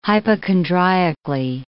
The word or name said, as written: hypochondriacally